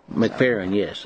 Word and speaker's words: McFerrin: McFerrin, yes.